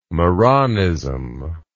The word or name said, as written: marranism